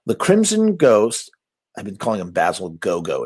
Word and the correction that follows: Gogos: It's Basil Gogos.